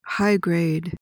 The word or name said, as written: high-grade